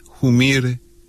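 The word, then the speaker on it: Humire: Joseph Humir, pero como se pronuncia originalmente en Bolivia, Humir es uno de los funcionarios más importantes del Pentágono, como se conoce al Departamento de Defensa de Estados Unidos.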